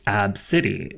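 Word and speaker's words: Abcde: Her daughter's name is a, A, B, C, D, E, which is pronounced Ab City, which happens to be my favorite local gym.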